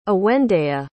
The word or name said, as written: awendea